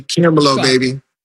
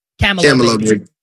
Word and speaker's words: Camelo: This is say it like this. Hold on, do it with me. Ready? Camelo, baby. – Camelo, baby.